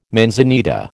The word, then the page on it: manzanita: American English